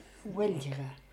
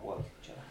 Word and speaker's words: Vilhelmina: Vilhelmina då, hur uttalar du det? – Welchere. – Är det Welchere, eller? – Ja. – Får höra en gång till. – Welchere. – Ja, ja, Welchere.